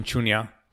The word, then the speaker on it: Shunya: Did I pronounce that right? – Yes, Mission Shunya.